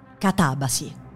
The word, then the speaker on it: catabasi: con l'accento elegantemente sdrucciolo. Catabasi.